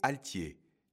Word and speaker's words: altier: Aujourd'hui, notre mot du jour est altier, qui s'écrit IER pour le son yé.